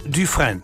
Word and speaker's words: Dufresne: D-U-F-R-E-S-N-E. Etwa ausgesprochen Dufresne. Dufresne, etwa so, ja? – Ja.